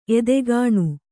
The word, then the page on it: ಎತ್ತುಗಾಣ: ettugāṇa; Kannada